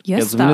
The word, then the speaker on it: Gösta: Gosta. – Ach, das spricht man Jöster ja, aus? Nee, zumindest nennt der Vater seinen Sohn immer Jöster.